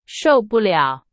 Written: 受不了

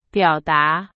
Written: 表达

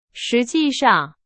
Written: 实际上

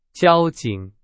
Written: 交警

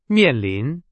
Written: 面临

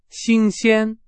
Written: 新鲜

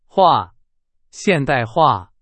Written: 化（现代化）

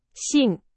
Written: 信

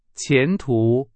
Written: 前途

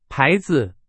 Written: 牌子

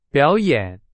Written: 表演

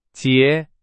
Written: 结

